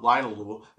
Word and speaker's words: linalool: Linalool, okay, which I've been pronouncing wrong for so long. Linalool, it's linalool.